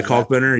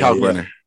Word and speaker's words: Kalkbrenner: Yeah, Kalkbrenner. Yeah, yeah. – Kalkbrenner.